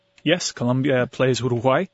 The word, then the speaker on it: Uruguay: So, Uruguay, am I pronouncing that correctly? – Uruguay. – Uruguay, sorry.